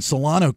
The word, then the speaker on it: Solano: He's like a big influencer on social media in Solano County. Am I saying that right? It's like Vacaville, right? – Yep.